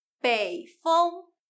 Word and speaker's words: 风: 这里我们读风，不是读分，北风。